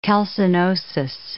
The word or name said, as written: calcinosis